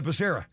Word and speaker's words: Becerra: Bacaria was the Housing and Urban Development Becerra, and they called him Bacaria. And then he said, Becerra.